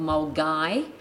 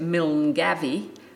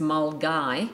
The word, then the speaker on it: Milngavie: The West Highland Way commences in a small place called Mulgay, actually spelt, and most people would actually say by looking at it, Milngavie, but it's pronounced Mulgay.